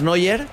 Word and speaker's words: Neuer: Manuel Noya era mi abuelo. Yo digo, se pronuncia, es que es Neuer. Pero se pronuncia casi igual, sí. – Sí, se pronuncia en Noya.